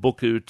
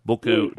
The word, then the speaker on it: Buchu: One thing you need to start using and possibly never go off, it's what's called Buku tea, and I'll spell it for you. – Thank you. – B-U-C-H-U. Buku? – B-U-C-H-T. – Yeah, B-U-C-H-U. Buku tea.